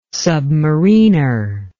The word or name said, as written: submariner